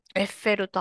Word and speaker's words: Eiffel: I'm trying to find how do the French pronounce Eiffel Tower? – エッフェルタワー。エッフェルでしょ？ – If yeah, damn it. Okay, you're right. – ほら、ほら、エッフェルだよ。 – Eiffel. – ほら、 Eiffel. – ほら。 – Okay, well, you just gloat then. – いや、じゃあこれはさ、ちょっとオフトピックスね、エッフェルタワーから。